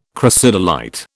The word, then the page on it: crocidolite: British English